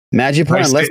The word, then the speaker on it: Madripoor: Falcon Winter Soldier episode 3 came out, and boy oh boy was it a fun little ride into the city of Madripoor. Am I saying it right? – Yeah, you're saying it right, dude. – Madripoor.